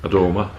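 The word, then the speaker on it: Adomah: The leading scorer is Albert Adama, is that how you say it? – Adama. – Adama.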